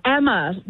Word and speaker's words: Emma: Daughter, it's Emma. Yeah, pronounced like hammer, but no H.